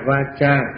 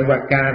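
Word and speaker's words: vācā: Dù là sam-ma-sang-cáp-ba xong, bây giờ tới chánh ngữ sam-ma-va-cha, chữ C-A nó đọc là cha va-cha chứ không phải va-ca đâu.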